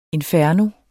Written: inferno

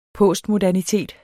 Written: postmodernitet

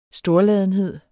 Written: storladenhed

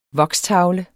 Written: VOKSTAVLE

